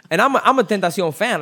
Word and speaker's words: Tentacion: Tentacion. – Tentacion. – XXXTentacion. – You can say it with a Spanish accent because it's a Spanish word.